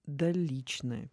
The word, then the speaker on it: доличное: В иконописи есть личное письмо и есть доличное письмо. И то, и то. – Ну, доличное, наверное, да? – Вот ударение стоит так. Доличное. Личное и доличное. То есть вот как витиевато ударение может стоять.